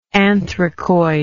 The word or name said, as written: anthracoid